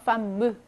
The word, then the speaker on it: femme: femme, 무 발음이 나는 거죠.